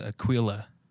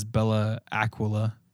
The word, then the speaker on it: Bella Aquila: And, no, it's, they pronounce it Aquila. – Aquila? – Yeah, white people in Idaho. – Okay. – But I guess how it's really pronounced is Bella Aquila. – Bella Aquila. That's how it's pronounced.